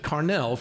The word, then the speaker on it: Carnal: It's the word carnal, C-A-R-N-A-L. They pronounced it Carnell for obvious reasons.